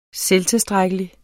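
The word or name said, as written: SELVTILSTRÆKKELIGT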